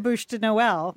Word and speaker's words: Bûche de Noël: I'll just go buy the Bûche de Noël.